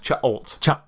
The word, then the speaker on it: Cha'alt: Chalt.